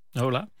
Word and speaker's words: hola: Hola. – Hola. – Hola.